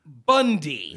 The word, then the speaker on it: Bounde: It's that our last name is spelled B-O-U-N-D-E, which is pronounced Bundy.